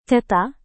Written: tata

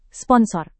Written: sponsor

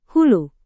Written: hulu

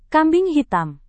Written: kambing hitam

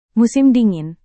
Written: musim dingin

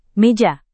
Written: meja

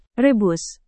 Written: rebus

